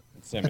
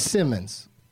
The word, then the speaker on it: Simmons: I always say this wrong, J.K., it's either Simmons or Simons. – Simmons. – I think it's Simmons. J.K. Simmons.